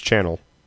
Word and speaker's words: Chanel: Chanel West, I think I'm saying it right. – Chanel? – Channel West? – It's Channel.